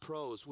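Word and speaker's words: pros (πρός): With is pronounced pros, which suggests to be in a company with, to be in relationship with.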